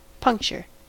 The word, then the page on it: puncture: American English